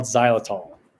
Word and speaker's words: xylitol: So one of the chemicals that's in your toothpaste, right, that's in your mouthwash, is a sweetener called xylitol. – I always wondered how to pronounce that. It's the one that's X, Y.